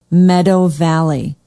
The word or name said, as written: meadow valley